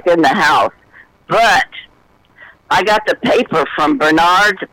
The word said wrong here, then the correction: Menards: is that how you pronounce it? Menards, yeah.